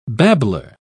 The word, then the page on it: babbler: British English